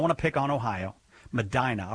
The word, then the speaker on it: Medina: If it's supposed to be pronounced Medina, I apologize. I never get those right. – Yes, it's pronounced Medina. – Nah! – Medina. – Yeah, like a normal person, but not a person from Ohio.